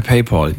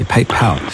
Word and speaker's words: PayPal: Ich schick's dir per PayPal. Jens, vielen Dank. – PayPal, gute Idee. – PayPal, sorry, ich vergesse jedes Mal, dass ich es falsch ausspreche. PayPal. Dir einen schönen Abend.